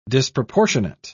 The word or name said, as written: disproportionate